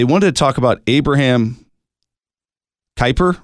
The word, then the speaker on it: Kuyper: I think some people pronounce it differently, but I would say Kuyper.